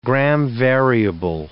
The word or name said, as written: gram-variable